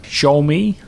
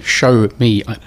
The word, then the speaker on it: Xiaomi: Now, the other one, and I always forget the pronunciation of this when I read it, Z-I-A-O-M-I, it sort of looks like... – Xiaomi, I think it is. – Okay, Xiaomi. – Effectively, show me.